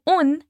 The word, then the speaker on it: un: O nosso un é escrito com N de nada, então quando você for pronunciar, coloca sua língua lá em cima, paradinha, atrás dos dentes da frente. Repete comigo.